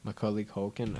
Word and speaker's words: Macaulay Culkin: Macaulay Culkin.